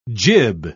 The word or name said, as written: jib